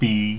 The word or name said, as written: bi